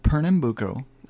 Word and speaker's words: Pernambuco: So that's called Pernambuco.